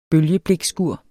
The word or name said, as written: bølgeblikskur